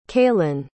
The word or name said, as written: caelan